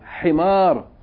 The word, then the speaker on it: حمار: Himar.